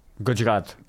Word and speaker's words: Gujarat: Gujarat.